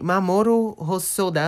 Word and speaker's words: Mamoru Hosoda: A direção é de Mamoru Hosoda. – Pronúncia é e... perfeita.